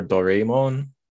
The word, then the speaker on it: Doraemon: Doraemon or Doraemon? – I would say Doraemon. – Doraemon, okay. – We're trying to pronounce it as close to Japanese.